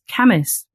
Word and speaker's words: Cambois: I remember there's a place in the Northeast, which is it's spelled C-A-M-B-O-I-S. And I pronounced it Cambois, much to my mum's hysterical laughter, because it's actually pronounced Camis.